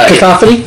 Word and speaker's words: cacophony: apparently his last story, Batman Cataphony or whatever, mm-hmm. Probably not saying that right. – Cacophony. – That actually sounds better.